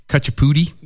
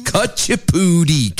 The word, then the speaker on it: Kuchipudi: And say it right. – Cut your pootie? – No. – That's cut your pootie. Kuchipudi. K-U-C-H-I-P-U-D-I. Kuchipudi.